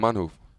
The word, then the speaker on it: Manhoef: Melvin Manhoef.